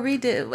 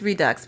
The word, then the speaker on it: Redux: Redux. Or Redo. I think it's Redux because I looked up the French pronunciation and it wasn't any different. R-E-D-U-X.